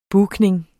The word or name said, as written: booking